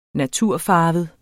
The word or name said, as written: natur